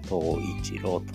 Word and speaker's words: 冬一郎: で、さて、東一郎っていうのは冬の一郎と書いて東一郎と呼ぶんですけれども、この名前の由来ですね。これについて、えー、ちょっとお話をし始めていて、で今クイズを始めました。東一郎